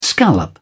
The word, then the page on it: scallop: British English